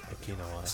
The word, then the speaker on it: Okinawa: Yakuza 3 set in Okinawa. That's how it's pronounced, right? – Yeah. Okinawa.